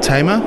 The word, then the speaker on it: Tam: Tam. The Americans say Tamer. – I'm going to go with Tam.